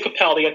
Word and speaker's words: Capaldi: Now, Doctor Who fans can see David Tennant talking in an interview, Peter Capaldi, whoever you have, I will say Capaldi, I think, as he pronounces it that way.